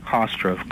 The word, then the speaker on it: Hofstra: Was it Hofstra University? I'm pronouncing it wrong. Hofstra, correct.